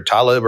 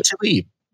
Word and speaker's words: Tlaib: Well, you've got, how do you say her name? Talib or whatever? – Yeah, so Rashida Tlaib.